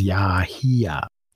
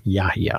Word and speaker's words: Yahya: Okay, Wikipedia says Yahya. – Yahya. – The emphasis on the first syllable. Yahya.